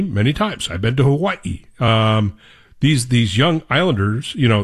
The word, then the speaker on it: Hawaii: Hawaii, because you have to pronounce it correctly. Hawaii, which is, by the way, how they want you to pronounce it.